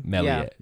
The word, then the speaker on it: Meslier: And I said Meslier, is that how you pronounce his name? – Meslier.